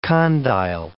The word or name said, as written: condyle